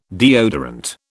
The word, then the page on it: deodorant: British English